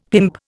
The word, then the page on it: pimp: Indian